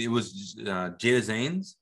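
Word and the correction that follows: Zeta-Jones: right? – Zeta Janes. – Am I saying it right? – Yes. – What did I just do? – You said Jada Zanes.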